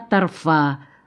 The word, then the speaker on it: торфа: Вначале это была торфа, как ее здесь называли, с ударением на последнем слоге.